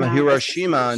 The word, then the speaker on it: Hiroshima: So from Hiroshima in 1945, am I saying that correctly? – Yes.